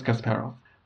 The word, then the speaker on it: Kasparov: I think the pronunciation is Kasparov. – Sounds good to me.